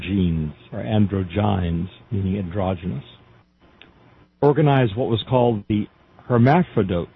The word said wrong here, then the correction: Hermaphroditos: Not hermaphrodites, it's spelled differently. I'm not equal to pronouncing some of this. Hermaphroditos, that's it, Hermaphroditos.